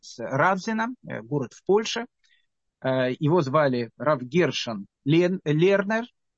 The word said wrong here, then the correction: Лейнер: Рав Гершон Лейнер, точнее, так он произносится, я думаю, рав Гершон Лейнер в 1887 году сказал, что он определил краситель, из которого делался тхелет.